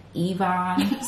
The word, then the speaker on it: Evonne: When I say my name to people, I'd be like, Evonne, so you know it's an E.